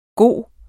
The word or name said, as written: bedste